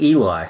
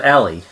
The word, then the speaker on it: Eli: I mean, I always actually pronounce it Eli because, I've read far too much American fiction and, you know, that... – When you're reading the book, Eli. It's clearly Eli. Mm. But in the film, she pronounces it Ellie.